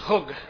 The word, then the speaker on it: chug: The word that's translated circle there in Hebrew is chug. Chug, like K-H-U-G if you were to spell it phonetically.